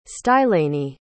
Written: stylanie

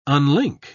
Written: unlink

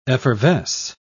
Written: effervesce

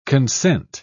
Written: consent

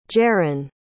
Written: jeran

